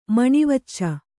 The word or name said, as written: ಮಣಿವಚ್ಚ